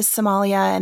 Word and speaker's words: Somali: But there are, you're close to Somalia, and there's a lot of Somalians in Djibouti, it sounds like. – Yep. They're the Somali is how they say it.